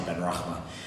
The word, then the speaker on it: Benrahma: Saeed Benrahma, to have... That's how you pronounce the name.